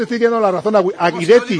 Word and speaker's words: Guidetti: No, vamos a ver, no, Pedrito Martín dice que si el apellido Guidetti se pronuncia en italiano es Guidetti. Otra cosa es que como él es sueco, al trasladarse a Suecia él prefiere que le llamen Guidetti.